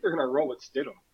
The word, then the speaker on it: Stidham: Stidham.